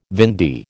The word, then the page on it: vendee: American English